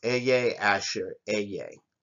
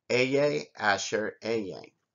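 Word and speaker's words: Ehyeh Asher Ehyeh: It's pronounced Eye Asher Eye. And what that term is I am who I am. I am that I am. Eye Asher Eye.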